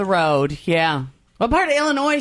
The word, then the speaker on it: Illinois: That drives Illinoisans crazy, though, when people say the S at the end.